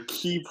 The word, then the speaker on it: Ki: KI points? Did I say that wrong? – They're key points.